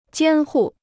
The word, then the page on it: 监护: jiān hù